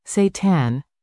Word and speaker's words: seitan: Seitan, pronounced Seitan, is a wheat-based meat alternative that has been around for at least 1,500 years.